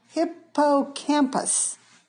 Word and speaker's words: hippocampus: Birds and mammals, yes, that includes humans, have what is called a hippocampus. – Yes, exactly. – Did I say that right, Kirsten? – Just perfect.